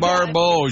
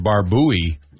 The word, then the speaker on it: Barbeau: So, Adrienne Barbeau, she was Maud's daughter. – Mm. – And some people pronounce it Barbuy. – Right. – Adrian Barbuy, but it's Barbeau. Like, it's it does look like that, but okay.